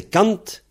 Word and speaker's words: kant: Donc, alors, 100, c'est Kant, K-A-N-T, Kant ou Kan. Souvent, quand un mot se termine par N-T, on ne prononce pas le T.